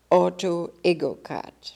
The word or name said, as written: Autoegocrat